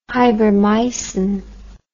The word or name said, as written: hybrimycin